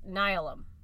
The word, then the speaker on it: Nihilum: The next confrontation between Sargeras and the Pantheon was Nihilum, which I finally figured out how to pronounce that because it's like the word, like, nihilism or like annihilation.